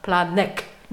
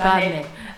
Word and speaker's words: Planet: Sag mal, Planet, nicht Planet. Planet, Planet. Okay, jetzt habe ich.